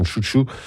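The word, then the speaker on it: Sun Tzu: Enfin, on le prononce en France Tzu Tzu, enfin, la plupart du temps. Les Chinois le prononcent Chou Chou.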